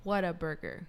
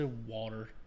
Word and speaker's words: Whataburger: That's not how you say it. – Why? – It's Whataburger. – Yeah. – What a burger. – Yeah, it's Whataburger. – Whataburger. – You sound like you said a wanna burger.